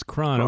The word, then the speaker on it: Kron: I see your last name is spelled K-R-O-N. Is it Brian Cron or Cron? – It is Cron.